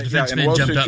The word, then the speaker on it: Nwosu: Now, in that case, luckily for them, Mahomes throws the interception, you know, where, you know, the Nwosu, is that how you say his name? – Yeah, Nwosu